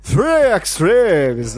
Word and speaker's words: Three Extremes: Three Extremes, na pronúncia correta, uma antologia em três partes, onde cada uma foi dirigida por um cineasta oriental, lá no ano de 2004.